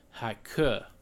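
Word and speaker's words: haike (黑客): In Chinese, this word is haike, and that's H-E-I-K-E is how you would spell it in English.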